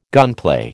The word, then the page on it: gunplay: American English